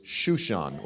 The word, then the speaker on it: Shush: And about 200 miles or so to the east is Shushan or Susa. Today it's called Shushan. Or um, actually, today it's uh, pronounced Shush.